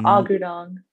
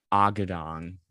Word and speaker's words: Agudong: And Agudong. – Agudong.